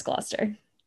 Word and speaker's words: Gloucester: I, I love that we are from Massachusetts because we know how to pronounce Gloucester. Gloucester, baby. That's right. Not Gloucester, people. Gloucester.